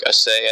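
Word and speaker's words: Asay: Mark Assay, I think is how you say his last name. – Yes.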